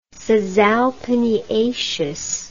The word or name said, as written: caesalpiniaceous